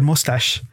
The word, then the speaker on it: mustache: And I'd never seen the word in print before and I wasn't familiar with it and I pronounced it as mouse tackle. It was the word mustache.